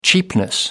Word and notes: cheapness: American English

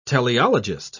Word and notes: teleologist: British English

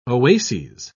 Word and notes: oases: British English

